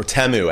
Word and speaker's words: Temu: Or Temu, as we all learned, was how to pronounce it yesterday.